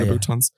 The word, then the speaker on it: Louboutins: Yeah, Louboutins? – Yeah.